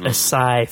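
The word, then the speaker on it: açaí: But it's pronounced a sigh for anyone wondering, because it has a little squiggle under the C, which makes it like an S.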